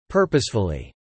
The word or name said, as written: purposefully